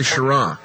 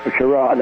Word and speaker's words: Schirra: Wally Schrider. I hope I said his name right. – Wally Schirra. – Schirra, that.